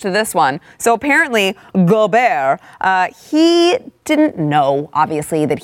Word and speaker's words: Gobert: Gobert, that's how they're telling me that it's pronounced.